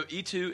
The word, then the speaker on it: y: Well, typically, so E2 is and you, and typically it's pronounced E, not ye two.